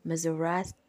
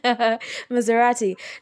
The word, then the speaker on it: Maserati: It's just the prestige that comes with saying Maserati. I just said it wrong. Maserati.